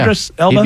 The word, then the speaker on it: Idris Elba: So, a lot of people wanted to be Idris Elba. Am I saying his name properly? – Yeah. – Idris Elba? – He'd be good.